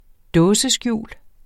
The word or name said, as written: dåseskjul